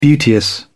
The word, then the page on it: beauteous: British English